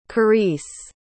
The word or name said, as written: karisse